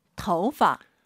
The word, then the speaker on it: 头发: 头发. – 오유는 어우예요. 토우 하지 마시고 터우예요. 이성경성이니까 아하, 头发. 재밌는 건 이 파를 사성으로 발음을 정말 많이 해요.